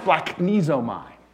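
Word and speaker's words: splachnizomai (σπλαγχνίζομαι): It's pronounced splachnizomai.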